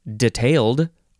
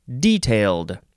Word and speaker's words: detailed: The first word in this first section of page 340 is either pronounced detailed or detailed. D-E-T-A-I-L-E-D.